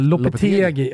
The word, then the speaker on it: Lopetegui: Vi pratade om tränaren Julien Lopetegui. Lopetegui. Ja, ja, exakt. Klarar aldrig av de där spanska uttalen.